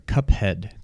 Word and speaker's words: Cuphead: Uh, Kufid, I think is how you pronounce it. Uh, oh, sorry, Cuphead. Cuphead. um, Cuphead.